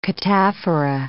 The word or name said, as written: cataphora